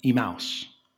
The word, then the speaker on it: Emmaus: Now, side note, in this country, we typically say Emmaus. When Linda and I went to Israel a few years ago, that's not how they pronounce it there. They giggle and mock us a little bit. They pronounce it Emmaus.